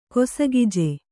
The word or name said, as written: ಕೊಸಗಿಜೆ